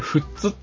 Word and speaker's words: 富津: これは富津市って言うんですかね。 – これ富津って読みます。 – 富津って言うんですか。 – はい。千葉の真ん中ら辺にこうすごい細長くですね、尖った岬があるんですけど、ね、そこが富津市です。